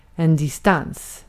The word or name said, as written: ara